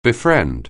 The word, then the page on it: befriend: anglais